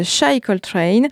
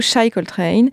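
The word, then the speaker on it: Chi Coltrane: C'est le cas de l'artiste qui va suivre, elle s'appelle Shy Coltrane, euh, ça s'écrit C-H-I, c'est comme le diminutif de Chicago, Shy Coltrane.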